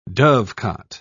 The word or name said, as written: dovecote